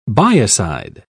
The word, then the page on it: biocide: British English